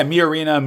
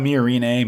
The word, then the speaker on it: Miorine: And she gets out of the aerial with Miorina. – Mm-hmm. – I pronounced that right, right? – Yeah, Miorina, Miorine, Miorine, any of those is right.